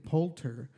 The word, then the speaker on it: Poulter: Uh, you know, speaking about the MCU, uh, Will Porter, uh, Will, how do you say it? Poulter, the the eyebrows guy from, um, the, you know, the British uh, guy with the eyebrows.